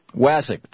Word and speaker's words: Wasik: Wasik.